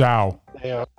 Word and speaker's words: Zhao: Directed by Chloe Zao? Is that the right pronunciation? Zhao. – Zhao. Oh, thank you. Yes, that makes more sense now that I know that that's Zhao.